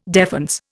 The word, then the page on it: defense: Indian